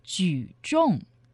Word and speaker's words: 举重: It's 举重, weightlifting. – 举重. – 举, the third tone, which means to lift. – 举. – 重, the fourth tone. Tong means the weight.